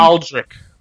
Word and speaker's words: Aldrich: Or what is the guy's name? – Aldric. – Aldric. Thank you. Yeah, I knew I was saying that wrong. I just couldn't think of how. – You were getting Aldrich, and Eldritch and all.